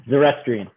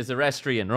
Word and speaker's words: Zoroastrian: Zoroastrian. – Yeah, Zoroastrian.